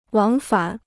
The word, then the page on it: 往返: wǎng fǎn